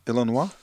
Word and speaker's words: Illinois: Dus onder andere Illinois. Zeg ik dat goed? Illinois. – Illinois ja.